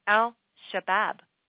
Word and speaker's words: Al-Shabaab: Now, two suspected members of Somalia's Al-Shahab, or actually, if you want the proper pronunciation, I can arrange that for you. Al-Shabaab.